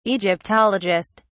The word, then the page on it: egyptologist: British English